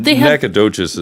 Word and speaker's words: Nacogdoches: But Nacogdoches is spelled the way you would think. It's just got a G in it.